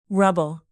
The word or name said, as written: rubble